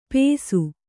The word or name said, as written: ಪೇಸು